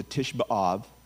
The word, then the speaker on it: Tisha B'Av: It was called that long before Titus came in, because that was the day, the same day, the Tisha B'Av, which is how you say it in Hebrew, the ninth of Av, that Nebuchadnezzar destroyed the temple the first time.